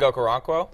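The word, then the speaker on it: Chig Okonkwo: I could see, and I'm gonna butcher his name. Okonkwo? – Oh, Chig? – Yeah. – Chig Okoronkwo? – Yeah, thank you.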